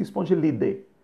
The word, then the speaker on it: Spongilidae: Existe um grupo da família Spongilidae, Spongilidae, que escreve dai com a e no final, Spongilidae. Então a gente pronuncia Spongilidae.